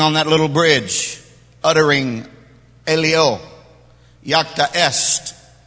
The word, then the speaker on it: Alea iacta est: And it's pronounced Alea Iacta Est.